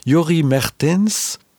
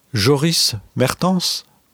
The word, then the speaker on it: Joris Mertens: Alors, je prononce plus ou moins la néerlandaise. Hein, Jori Mertens. En français, on dirait Joris Mertens. Mertens, peut-être, en faisant un tout petit effort.